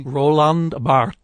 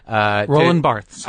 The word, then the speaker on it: Barthes: Roland Barthes. – Thank you. – Roland Barthes.